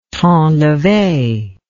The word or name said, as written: temps levé